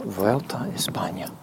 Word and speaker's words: Vuelta a España: Vuelta a España.